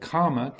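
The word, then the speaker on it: kāma: So karma tanha, that's, and also it's important to distinguish between the word karma, K long A M A, which means sense pleasure, and then kamma, which is K double M A, which means action, or the Sanskrit for that is karma.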